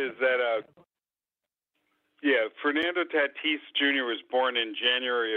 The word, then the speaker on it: Tatis: So, uh, the guy in the Padres, Scott, it's Fernando Tatis. You do pronounce the S. You don't uh, leave the last S off for savings. – Right. Okay. Tatis.